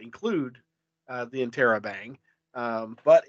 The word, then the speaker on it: interrobang: And the pronunciation on Google is interrobang. – Interrobang. Okay. Yeah. Not interrobang. It's interrobang.